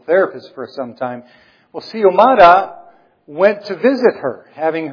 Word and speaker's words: Xiomara: The lady Xiomara, in case you're wondering how to pronounce, the X-I-O-M-A-R-A, that X, she pronounces it like it's an S, Xiomara.